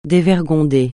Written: dévergonder

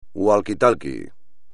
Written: walkie-talkie

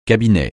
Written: cabinet

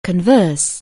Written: converse

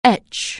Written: etch

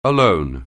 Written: alone

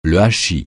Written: hachis

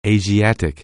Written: Asiatic

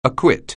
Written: acquit

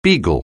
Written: beagle

